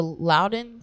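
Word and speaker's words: Loudoun: They lost to Loudon. Am I saying that right? – Yes.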